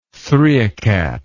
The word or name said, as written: three old cat